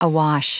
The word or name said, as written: awash